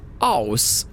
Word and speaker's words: Åhus: Aus tror jag att det ska uttalas. Alltså A-O-S.